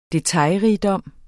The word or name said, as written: detaljerigdom